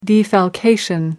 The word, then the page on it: defalcation: American English